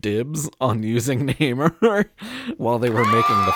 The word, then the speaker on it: Namor: It's pronounced Namor.